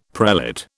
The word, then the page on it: prelate: British English